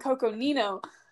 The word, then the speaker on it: Coconino: Cocosino. Or did I say that right? Oh, dang it. I would say Cocosino and I mean Coconino.